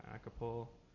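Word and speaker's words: Achelpohl: You can just Google my name, which is John Acapul.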